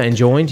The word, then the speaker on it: enjoined: Will this decision lead me to be more worldly or more enjoyed to unbelievers? Did I say that right? – Yeah, enjoined. Yeah.